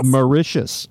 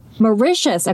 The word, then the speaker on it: Mauritius: Mauritius. – Mauritius.